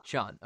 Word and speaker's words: Sean: Is it Sean or Shane? – Sean. – Okay.